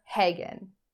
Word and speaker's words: Hagen: So they contacted a priest named Father Hagen, which rhymes with Megan, who contacted his superior, who got in touch with Ed and Lorraine Warren, who were famous demonologists.